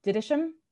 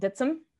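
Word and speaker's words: Dittisham: The town across the river from Greenway, to me, would have been called Didisham, but apparently it's called Ditsum by the people who live.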